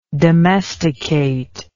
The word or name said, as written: domesticator